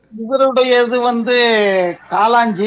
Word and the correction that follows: காளாஞ்சி: ஐயா. – வாழ்த்துக்கள், வாழ்த்துக்கள் வாழ்த்துக்கள். காளாஞ்சி என்று கூறுவது அதுக்கு பெயர் காளாஞ்சி. ஞாபகம் இருக்கும் எங்கள் நாகப்பருக்கு என்ன கௌரி வந்து முன்பு இதை இருக்க சொல்லியிருந்தார் இல்லையா ஒரு தடவை பொக்கிஷத்தில்.